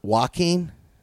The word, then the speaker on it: Joaquin: It's a silent J. – Joaquin? – You got it.